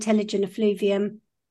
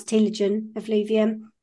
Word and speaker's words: telogen effluvium: So there's a condition called telogen effluvium, which some people pronounce telogen effluvium.